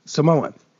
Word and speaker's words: Samoa: Now, for bonus credit, Samoan is never properly pronounced as Samoa, as many mainlanders do. Properly said, it's Sa-Moa.